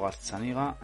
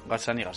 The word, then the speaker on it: Gazzaniga: Lo voy a repasar por si acaso, pero, pero juraría que es Gianfranco Gazzaniga. – Gatsaniga, eh, eh, Gazzaniga, sí. Es que a veces digo Gazzaniga, a veces digo Gazzinaga.